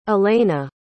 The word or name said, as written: elena